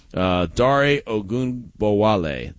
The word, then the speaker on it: Dare Ogunbowale: But, Dare Ogunbowale, that's how you say his name.